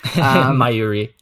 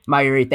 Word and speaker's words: Mayuri: Mayuri. – Mayuri, thank you.